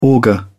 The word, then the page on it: auger: British English